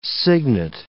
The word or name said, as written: cygnet